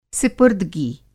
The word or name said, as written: सिपुर्दगी